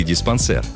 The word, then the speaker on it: диспансер: Диспансер или диспансер? Где ударение? Многим так и хочется поставить его на второй слог, на «а», но правильный вариант – «диспансер». Это слово пришло к нам из французского языка, где означало «аптека», «лечебница». Изначально диспансеры были благотворительными учреждениями, где выдавали бесплатные лекарства. Французский диспансер восходит к латинскому «dispenso» – «распределять», «регулировать», «взвешивать». Но французы ставят ударение на последний слог.